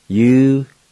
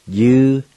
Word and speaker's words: uduli: And it's spelled A-N-A-T-L-I. A-N-A-T-L-I. Number three, he or she wants it. And it's spelled U-D-U-L-I. U-D-U-L-I. Number four, he or she wants them. D-U-D-U-L-I. D-U-D-U-L-I.